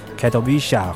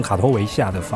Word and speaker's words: Katowice: 那今天这一场呢，我们是在今年的联合国的气候会议的现场哦，COP twenty four，在这个波兰的啊，中文翻译叫卡托维兹，其实它的当地念法叫 Katowice 哈，其实比较像卡托维夏的发音啊，我跟大家在这边做个 local 的纠正一下，这样子。